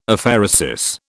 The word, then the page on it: Aphaeresis: British English